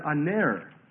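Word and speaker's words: Aner (ἀνήρ): And this word man is the Greek word aner.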